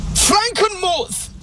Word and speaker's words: Frankenmuth: But there is a German town, a little Bavarian place called Frankenmuth. That is the exact pronunciation.